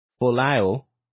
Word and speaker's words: Folau: Now, this is a rugby player, Israel Folau, yeah, that's how you pronounce his name.